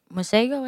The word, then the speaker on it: Masego: I need to get more into Masego, is how you say his name? – Yes.